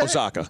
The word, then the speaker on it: Osaka: Osaka.